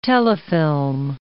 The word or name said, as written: telefilm